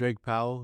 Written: Drake Powell

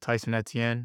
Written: Tyson Etienne

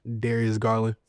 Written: Darius Garland

